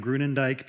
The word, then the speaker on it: Groenendyk: In my last name, Groenendyk, people usually do the same thing.